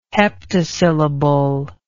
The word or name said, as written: heptasyllable